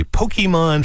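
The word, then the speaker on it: Pokémon: They just opened a Pokemon fossil museum. Listen, the correct way to say Pokemon is actually Pokemon, not Pokemon, but most of us say Pokemon, so that's what I'm going to say.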